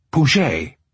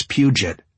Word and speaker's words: Puget: Puget. – "Nope. It's Puget. You limeys just kill me."